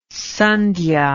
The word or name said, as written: sandhya